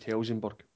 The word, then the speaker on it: Helsingborg: I'm not sure if you pronounce the S at the end. – Only if you're putting IF at the end of it, apparently. And if you drop the IF, it's just Helsingborg.